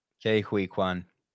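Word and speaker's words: Ke Huy Quan: Gavin Mann says two other memorables from the 80s, Yaphet Lotso and Kei Hui Kwan. Did I pronounce that right? – Kei Hui Kwan.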